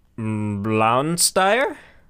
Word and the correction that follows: Blauensteiner: Blauensteiner, yeah.